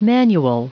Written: manual

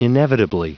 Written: inevitably

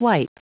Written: wipe

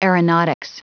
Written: aeronautics

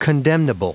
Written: condemnable

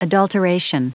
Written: adulteration

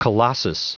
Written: colossus

